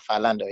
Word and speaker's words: Philando: Yeah, like is that how you say his name? – My bad, Philando, yeah.